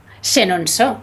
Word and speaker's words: Chenonceau: Bueno, fuera de antena me has dicho que no debería perderme el castillo de Chenonceau. – Chenonceau, muy bien, muy buena la pronunciación.